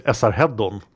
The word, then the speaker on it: Esarhaddon: But in Swedish, we say Esarheddon.